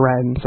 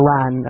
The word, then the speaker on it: Wrens: the Wrens, or the Ran, as my West Cork cousins pronounce it,